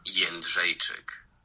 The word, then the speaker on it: Jędrzejczyk: Joanna Jędrzejczyk.